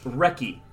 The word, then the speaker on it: recce: It's pronounced recce, which is a very British military term that is a short slang for reconnaissance.